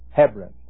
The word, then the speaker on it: Hebron: Hebron, some of you pronounce it. H-E-B-R-O-N. Hebron.